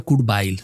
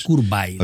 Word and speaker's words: Kurt Weill: Pronuncia Kurt Weill. – Ah, no es Weill. – No es Weill ni Weill. – No, Weill ya sé que no es. – Es Kurt Weill.